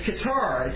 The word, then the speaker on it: Keturah: It's Keturah. It's K-E-T-U-R-A-H. Let me spell it to you one more time. K-E-T-U-R-A-H.